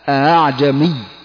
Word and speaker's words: أعجمي: قرأ حفص هذه الكلمة بتسهيل الهمزة الثانية الهمزة الثانية قرأ بالتسهيل بينها وبين الألف، يعني ننطقها بين الهمزة والألف، وهذا يضبط بالتلقي والمشافهة على شيخ متقن. لا تقول ليست بين الهمزة والهاء، من قال بين الهمزة والهاء فهذا خطأ، الصحيح بين الهمزة والألف، فتقول أعجمي، يعني